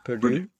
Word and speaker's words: Purdue: it was to young Republicans at Purdue. How do you pronounce that? Purdue? – Yeah.